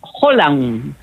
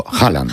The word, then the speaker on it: Haaland: Escrito A-A-L-H-A-A-L-A-N-D. – O sea, para todos los aficionados, Haaland. – Haaland, claro. Si es que lo decimos todo mal. – Pues hay que explicar que las dos A seguidas en noruego se pronuncian como O.